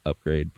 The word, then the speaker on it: Upgrayedd: And Rita, who is played by Maya Rudolph, a prostitute terrified of her pimp, played by Scarface, who's pimp's name is Upgrade, pronounced Upgrade, but it's spelled UPGRAYEDD, with an emphasis on the two D's for his double dose of his pimping.